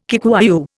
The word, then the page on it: Kikuyu: Indian